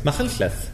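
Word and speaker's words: Machynlleth: How do you pronounce it? – McCunclith. – Oh, very good.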